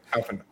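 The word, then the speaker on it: Alphinaud: So the girl who had the staff sword was Alisei, and the one with the sage class with the funnels, that's Alphanaud. I'm hoping I'm pronouncing that right. – Yeah, Alphanaud.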